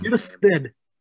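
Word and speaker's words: Kjerstad: Kishsten. Yes, you got to say it as if you know how to speak, like, with a Scandinavian accent.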